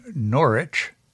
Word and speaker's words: Norwich: Well, I think that's how Americans pronounce it. But we say Norwich, so it sort of rhymes with porridge. – Why is the novel set in Norwich as opposed to, say, Ipswich?